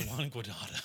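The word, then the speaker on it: Juan Guaidó: It's Juan Guaido.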